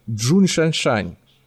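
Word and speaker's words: Джун Шаншань (钟睒睒): Он, он пишется по-английски как Жонг Женшень, а по-русски он звучит как Джун Шаншань.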